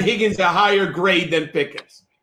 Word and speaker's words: Pickens: So people forget that uh, Pickens rhymes with Higgins.